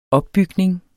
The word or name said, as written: opbygning